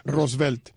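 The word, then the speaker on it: Rortvedt: No voy a decir Roosevelt porque no es como se pronuncia. Roosevelt.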